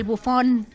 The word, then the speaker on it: Buffon: Pues es un nombrezazo, nuestro querido italiano, el Buffon.